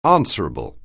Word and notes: answerable: anglais

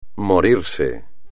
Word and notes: morirse: espagnol

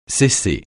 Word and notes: cesser: francais

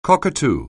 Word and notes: cockatoo: anglais

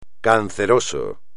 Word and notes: canceroso: espagnol

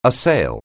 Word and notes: assail: anglais

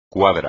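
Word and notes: cuadra: español

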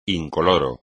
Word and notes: incoloro: espagnol

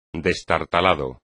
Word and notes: destartalado: espagnol

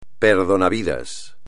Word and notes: perdonavidas: espagnol

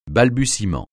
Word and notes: balbutiement: francais

